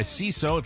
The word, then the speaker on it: Seeso: Welcome back to the Mike O'Mara Show, brought to you by CISO.